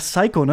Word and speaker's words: Psycho: Ja, ich nehme es mal vorweg, ich finde, man muss American Psycho, man sagt Psycho, ne? Nee, the P is silent.